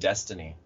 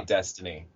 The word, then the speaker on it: Deathtiny: Apparently it's pronounced Destiny, if it is spelled Death. Destiny, they say Destiny, and you sound like a noob if you don't just say Destiny.